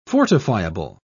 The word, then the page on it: fortifiable: British English